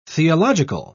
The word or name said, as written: theological